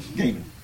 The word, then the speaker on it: Gaymond: Gaiman. – How spell it? – G-A-Y-M-O-N-D. – Gaiman. – M-O-N. Okay. – M-O-N-D. – Oh, Gaiman. Okay. Gaiman.